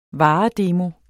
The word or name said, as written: varedemo